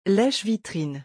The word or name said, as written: leche-vitrines